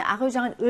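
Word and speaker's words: argent: 3번은 아르장이라고 해서 쥬 발음이 나죠.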